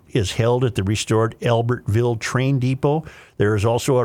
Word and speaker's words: Albertville: Every Thursday evening during the summer, the Albertaville. Why am I mispronouncing Albert? – Albertville. I'm sorry.